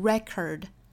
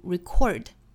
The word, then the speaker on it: record: 那这里我们再来讲一下 record 美音的发音，这个词作为名词在美音里仍然是低音节重读的，读 record, 一般这个词在美音里只有这一种发音，但是作为动词呢仍然是重读在第二个音节，读 record, record 和 record。